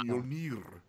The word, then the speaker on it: Mjolnir: I'm the guy that went to fucking Oslo and was corrected by an Icelander that I was pronouncing Mjolnir incorrectly, and he was like, wow. Mjolnir.